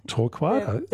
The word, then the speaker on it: torquata: There's even really small mallee trees like Eucalyptus torquata. – Torquata.